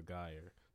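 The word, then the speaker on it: Guier: Yeah, Amber Geyer.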